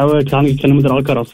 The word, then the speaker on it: Alcaraz: Alvarez. – Alcaraz, ja. Ja, komm, da dürfen wir beide Augen zudrücken. – Ah, nein, ich habe falsch ausgesprochen, ja.